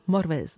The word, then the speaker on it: Morfydd: Je ne vous garantis pas ma prononciation à 100%, mais alors que son nom s'écrit Morfydd, Y2D, F-Y2D, on prononcerait quelque chose comme Morwedd.